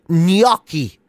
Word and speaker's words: gnocchi: Apparently, you're supposed to put a Y in it. So apparently, it's like gnocchi.